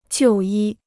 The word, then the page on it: 就医: jiù yī